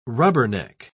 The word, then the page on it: rubberneck: British English